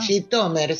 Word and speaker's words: Zhytomyr: En este caso vamos a hablar de una localidad bastante famosa, Zhytomyr o Zhytomyr, ¿no? ¿Cómo se pronuncia? – Zhytomyr, sí, Zhytomyr decían los judíos oriundos, digamos, en Yiddish, aunque probablemente en el lengua original del país es Zhytomyr, pero los judíos se referían a eso, a ese pueblo, a esa ciudad, como Zhytomyr.